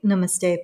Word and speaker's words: namaste: So when we take namaste particularly, in general, it is pronounced with emphasis on the second syllable. So like na, N-U-H, ma, M-U-H, and then ste, S-T-H-E, but short, like so na, na, ma, ste.